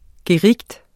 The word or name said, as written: GERIGTENS